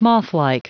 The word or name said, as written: mothlike